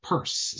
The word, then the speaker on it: Pierce: I forget now his first name, but his name was actually pronounced Purse. So at least that's how he pronounced it.